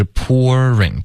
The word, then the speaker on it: pouring: When the offers were pouring in 后面就没有变化了 OK, 可是 pouring 大家注意一下有两种发音 OK, 第一是 pour 结尾加一个儿化音 pour 然后再来 ring, pouring, OK?